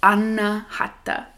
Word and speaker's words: Anahata: And so you say each syllable in your mind, because there's four syllables, you say each syllable with each part of the breath. So it's like A-na-ha-ta.